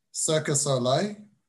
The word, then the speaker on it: Cirque du Soleil: And another example of it that, well, I was just about to touch on is Cirque du Soleil.